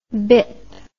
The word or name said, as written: bip